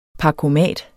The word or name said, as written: parkomat